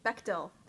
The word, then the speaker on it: Bechdel: And then with the men ones, there's this thing called, I might pronounce this wrong, but the Bechdel test? – Bechdel, thank you.